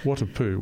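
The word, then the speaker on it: Whatipū: Watapoo, where is that? – Well, we would pronounce it more with a F sound than a W sound.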